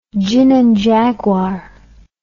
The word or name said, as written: gin and jaguar